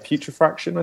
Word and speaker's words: putrefaction: between petrifaction and black petrifaction. – Yeah. – Is that how you pronounce that? Petrifaction? – Putrefaction, I think it was.